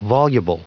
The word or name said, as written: voluble